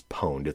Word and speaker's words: pwned: Um, and that is spelled like have I been owned, but it's it's pwned. It's a hacker term. It's P W N E D, pwned.